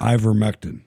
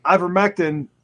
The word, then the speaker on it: Ivermectin: And they've already come out and said, I can never pronounce it. – Ivermectin. – Ivermectin. There you go.